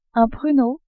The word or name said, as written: pruneau